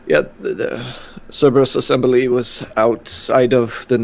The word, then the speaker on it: Cerberus: Would I have seen the Cerberus? How do you say that? – Cerberus.